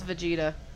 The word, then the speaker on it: Vegeta: That's Vegeta.